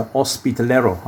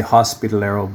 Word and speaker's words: hospitalero: Tell us about your journey as a hospitalero. I'm pronouncing that in the Spanish way. Canadians would say hospitalero, but the Spanish wouldn't understand that.